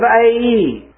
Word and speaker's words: Ai: The very second city they go up against is the little city of Ai. And that is the proper pronunciation. I finally learned this last week how to pronounce that correctly. The city of Ai.